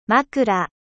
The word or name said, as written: oreiller